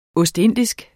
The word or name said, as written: ostindisk